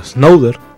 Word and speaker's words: Snowden: Prácticamente sobre el caso Snowden, así se pronuncia.